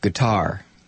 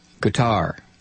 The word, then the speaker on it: Qatar: But I'll tell you what, Chuck, from here on out, I will pronounce it Qatar. Qatar.